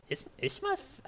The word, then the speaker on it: Isthmus: But another branch nearby, who is, I believe the name would be pronounced Ishmus? I-S-T-H-M-U-S? Ishmuff? I don't know.